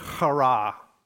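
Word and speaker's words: chara (χαρά): You got to get a little phlegmy kind of thing in your voice to say it right, but it's this word chara. That's the Greek word for joy, which almost sounds like you're saying hurrah, right? Or hooray.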